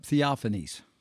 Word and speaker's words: theophanies: Yep, theophanies.